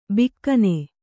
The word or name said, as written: ಬಕ್ಕನೆ